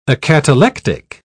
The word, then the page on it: acatalectic: British English